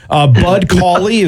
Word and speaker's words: Cauley: Bud Cauley, that was his first career win yesterday. Am I saying that correctly? – Yes. Yeah, you are.